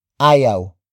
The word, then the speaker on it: ayaw: Ayaw matulog ni Chris. Ayaw matulog ni Chris. The first word is ayaw. Ayaw. Ayaw. Ayaw is spelled as A-Y-A-W.